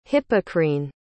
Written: hippocrene

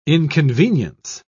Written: inconvenience

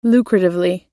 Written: lucratively

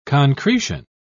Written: concretion

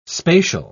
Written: spatial

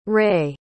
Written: rei

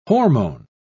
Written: hormone

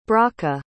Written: bracha

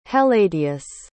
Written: helladius

